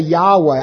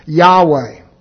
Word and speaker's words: Yahweh: That's why when I pronounce it that way, the tetragrammaton, I say Yahweh. I don't just talk about the four consonants. I put the vowels in there. That's the most likely pronunciation of the word in Hebrew, Yahweh.